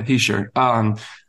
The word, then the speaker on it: Hischier: That's how you say it, by the way, Hischier.